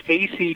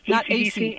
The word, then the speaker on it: Asay: You said it's AC, like AC. – Correct. AC. Just think AC, and it's perfect.